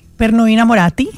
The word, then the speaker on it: Per noi innamorati: Y es una adaptación de una canción que en italiano, ahí me va a ayudar, se llama Per noi innamorati. – Per noi innamorati, sí.